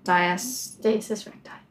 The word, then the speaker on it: Diastasis recti: How do I say it? – Diastasis recti. – Yes.